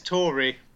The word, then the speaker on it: Tauri: Is it pronounced Tauri? – I don't know. There's an A. – It's Tauri. – There's an A. – I think it's AlphaTauri.